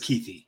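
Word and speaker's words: Kuithe: I thought it was Kuthi. – There we go. – I feel like a Utah fan told me it was Kuthi.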